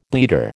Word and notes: bleeder: American English

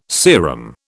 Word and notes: serum: British English

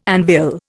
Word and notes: anvil: Indian

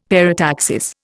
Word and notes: parataxis: Indian

